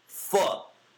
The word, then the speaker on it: pho: P-H-O. The word is P-H-O. It's pronounced pho. – Pho. It's like the other noodle place is called Forever Noodles. That's how I remember. – Yeah. – Forever. It's not called pho.